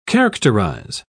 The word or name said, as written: characterize